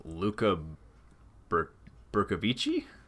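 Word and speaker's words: Luca Bercovici: Which was directed by, I'm going to butcher this, but Luca Brkovici, maybe? – That sounds right. – Luca Brkovici, and produced by, you know, the one and only Charles Band.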